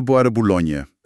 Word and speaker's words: Bois de Boulogne: En de Bois de Boulogne.